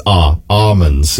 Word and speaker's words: almonds: And I said, she's like, you pronounce the L, Orina. – That's a good point. Yeah. No, she's wrong. No, you do not. It's almonds. Yeah. There's no L.